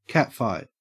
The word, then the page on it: catfight: Australian English